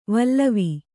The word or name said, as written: ವಲವ